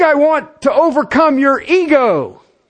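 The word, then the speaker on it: ego (ἐγώ): The word is spelled Epsilon Gamma Omega. It's always translated into English as a personal pronoun. It's pronounced ego.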